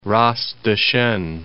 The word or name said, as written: Ras Dashen